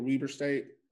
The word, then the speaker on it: Weber State: Am I saying it right, with Weber State? – That's correct.